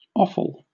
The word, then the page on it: offal: British English